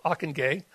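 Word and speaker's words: Ockenga: In the June 1947, Harold John Ockengay, and that is how you say his name, the pastor of Park Street Church in Boston.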